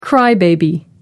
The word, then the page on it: crybaby: British English